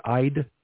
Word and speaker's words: Eid: The first one, the first word EID, it shows a long I. So it like I'd, yeah, I was trying to get the pronunciation of it online.